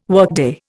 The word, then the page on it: workday: Indian